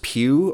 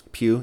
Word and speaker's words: Pugh: And Florence Pugh, or Pug, I don't know how to say her last name. She's the one who did Lady Macbeth. – Pugh, yeah.